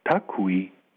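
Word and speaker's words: tacui: Sie stehen für die 5 Buchstaben von Tatsui, oder nein, falsch ausgesprochen, Takui, ich habe geschwiegen, die den Fundort offenbarten, als man ihn in die Moldau geworfen hatte.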